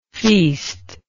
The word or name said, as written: feastless